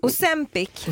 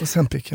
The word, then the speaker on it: Ozempic: På tal om Botox och Ozempic, har vi några samarbeten med dem kanske? – Vi har ju det. Jag var tvungen att plantera Ozempic. Men vad säger du? – Ozempic. – Ozempic, ja.